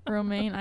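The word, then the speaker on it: Romain: Scarlett Johansson is engaged to a journalist boyfriend, Romaine, like the lettuce.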